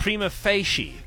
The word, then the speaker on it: prima facie: It's prima facie. – No. I'm not saying that is the actual pronounce. That's just an acceptable pronunciation.